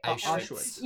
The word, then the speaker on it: Auschwitz: Auschwitz.